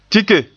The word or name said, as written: tike